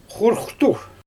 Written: xorxtur

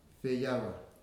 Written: Feawa

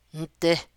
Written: ndi

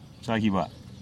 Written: chagiba

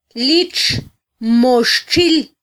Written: litxmoxtxil

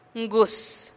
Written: nguus

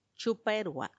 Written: chuppaerua

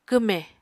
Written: kwmè

